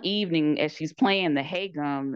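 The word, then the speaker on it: Hae-gum (해금): Okay, so Dongyi is now a palace servant, and she plays an instrument called the Lo had to help me out with this pronunciation. I'm probably still going to butcher it, but Hae-gum. – Hae-gum. I would say the same thing. So my pronunciation is no better, just so you know. But I would say the same thing. Hae-gum.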